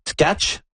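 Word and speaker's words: Tkáč: C'est ça, parce que là, on dit tac, mais c'est pas tac. – C'était pas tac. À l'époque, on disait tac, je pense qu'on l'avait francisé pour ça simplifier les choses, mais donc, la façon de le dire, c'était bel et bien tkach.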